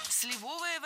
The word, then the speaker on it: сливовое: До его дедушки Степана Николаева или Степана Николаевича, который был крепостным крестьянином и, как известно, варил очень вкусное сливовое варенье и абрикосовую пастилу. – Сливовое варенье – это ошибка. Ударение в нашем прилагательном должно падать на первый слог.